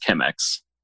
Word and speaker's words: Chemex: Is Chemex or Chemex? Is it CH or Chemex? Is it pronounced? – I believe it's pronounced Chemex.